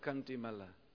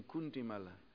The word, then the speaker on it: Kantimala: This is called Kantimala. Sometimes you hear the devotee sing Kuntimala. It's not Kuntimala. It's a real short A, so the Brishbazi sometimes they practically swallow that A, so they Kuntimala.